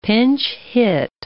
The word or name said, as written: pinch-hit